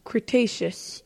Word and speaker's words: Cretaceous: Cretaceous, yes.